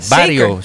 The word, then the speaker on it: barrio: Barrio, zeg ik het goed? Barrio.